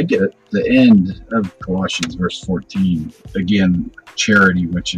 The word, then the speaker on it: Colossians: And it's also those Bible verses in Colossians.